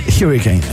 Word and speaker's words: Hurricane: Hurricane. Sí, así se dice.